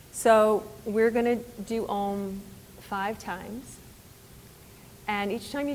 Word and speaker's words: om: So om is Sanskrit. It's actually three syllables, A-U-M, with a focus on the M.